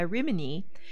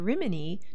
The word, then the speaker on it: Rimini: The post office was approved, but the miners changed the pronunciation of Rimini to Rimini, and it stuck.